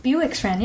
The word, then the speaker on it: Bewick's: And, for example, we have Bewick's Wren. Am I saying that right? – Bewick's Wren, yeah.